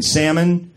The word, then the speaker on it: salmon: Number one, you go to the restaurant, the waitress pronounces the L in salmon.